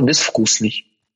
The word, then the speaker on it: безвкусный: Потому что «безвкусный», да, пишется «з», «безвкусный». Хотя произносится «с». Оказывается, правило, оно про букву, а не про звук. Буква «в» пиши «з», хотя звук-то там «ф». Безвкусный.